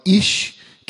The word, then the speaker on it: Ish: É, a palavra Iscariotes vem da junção de duas palavras hebraicas, a palavra Ish, que significa homem.